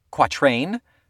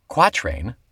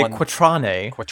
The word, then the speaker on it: quatrain: And those two tracks are quatrain or quatrain. The emphasis on the qua. – I think it's good either way. – I like either one. Go back. – As long as you don't say quatrane.